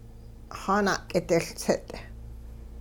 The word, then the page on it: xaana'k'idiLtsit: Hupa